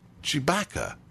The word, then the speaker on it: Tshibaka: Her name, she told me, it's spelled T-S-H-I-B-A-K-A, but she said, it's okay if you're a Star Wars fan. If I'm a Star Wars fan, it's pronounced Chewbacca.